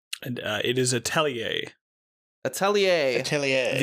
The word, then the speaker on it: atelier: The depending on whether you're British or American, according to, Google, you put emphasis on different syllables. But as an Australian, I'm just going to say Atelier. But okay.